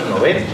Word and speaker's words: Nobel: Es una palabra aguda, pues no llevaría una tilde en la O. Así que por favor, que es una Muchas gracias. Es una cruzada en la que me empeño desde hace tiempo. La pronunciación en sueco es Nobel y hay que mantener el, término.